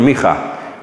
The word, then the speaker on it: Micha: It comes from the name Micha. That's how you pronounce it in Hebrew, Micha, which is short for Mikael, which is the Hebrew version of our English name, Michael.